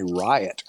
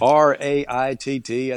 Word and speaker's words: Raitt: And I said Bonnie Riot. I thought it's R A I T T, I think is what it is, but it's Bonnie Raitt.